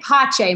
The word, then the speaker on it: Pace: B-O-B and Liz. Oh, and Pache, my dog. – How do you P-A-C-E. Spell that? How do you spell that? – It's P-A-C-E. It's peace in Italian and Latin.